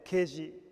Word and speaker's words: 啓示: 啓示というのは開く、啓発の啓に示すと書いて啓示と読みますが、啓示というのは神様がご自身を人に分かるように表してくださることを言います。